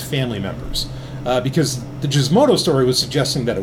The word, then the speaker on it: Gizmodo: Um, I know it's pronounced Gizmodo, folks, but anyway, we were, we're moving on.